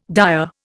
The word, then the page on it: dire: Indian